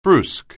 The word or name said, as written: brusque